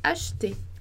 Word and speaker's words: acheter: Então, acheter, acheter, ele não tem som, porque ele não tem acento. Então, a gente não pronuncia esse E, ele vai ser mudo. Então, a gente vai falar acheter, acheter, acheter. tá? Vou colocar entre barras aqui como se fosse a pronúncia. Ash, ashte, ashte, ashte, ok? Então não é nem achete, nem achete, é ashte.